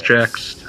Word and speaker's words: Gex: It's pronounced Gex, and it's not a dinosaur.